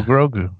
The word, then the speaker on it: Grogu: And I go, Grogu.